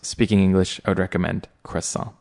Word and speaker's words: croissant: In the US, people tend to pronounce it like croissant. They'll pronounce the T on the end, which to French speakers sounds very bad, sounds very harsh, because in French, many of the letters at the end of a word, you don't pronounce them. And this is a French word. So I personally would recommend pronouncing it as a croissant, so not pronouncing the T. And if you want to really sound super French, it is a croissant.